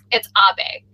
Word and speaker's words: Abe: It's Abe.